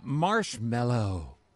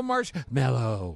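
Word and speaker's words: Marshmello: How do you know Marshmallow? – I read the full lineup this morning. – Why are you saying it like that? – Because it's spelled M-E-L-L-O. – Oh. – Marshmallow.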